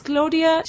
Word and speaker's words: Claudia: Yes, it's Claudia Cilatti. – Could you spell that, please? – Yes, it's C-L-A-U-D-I-A for Claudia.